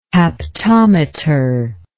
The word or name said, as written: haptometer